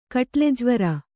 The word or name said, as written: ಕಟ್ಲೆಜ್ವರ